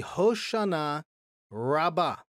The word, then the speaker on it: Hoshanah Rabbah: In Hebrew, it's called the Hoshanah Rabbah. Now, Hoshanah Rabbah, I'll explain this word in a minute. We would pronounce it, or we would translate it, the Great Hosanna. So Hebrew, Hoshanah, English, anglicized English, Hosanna. Okay?